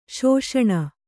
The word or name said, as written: ಶಾಸನ